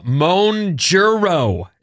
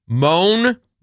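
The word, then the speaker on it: Mounjaro: Monjuro is how you say it. Monjuro. – That's an Ozempic drug. – The weight loss whatever. Drug similar to Ozempic is Monjaro.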